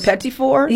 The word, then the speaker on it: petit four: But is it the petite four? Is that how it's said? – Oh, do you make those? – The petit four? – Yes, thank you.